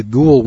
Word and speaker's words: Gould: Now, one of the advocates of this flaky idea is a guy named David Gould, G-O-U-L-D, spelled or pronounced Gould, who has a web page named The Amazing Vision of David Gould, where he promotes this trash.